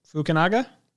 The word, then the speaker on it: Fukunaga: Cary Fukunaga. Fukunaga? – That, that's actually, you know, for a first time Fukunaga? out, that's really impressive.